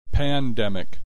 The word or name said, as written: pandemic